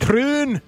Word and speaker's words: Troon: Is that how they say it? – Troon. – Troon. – Royal Troon.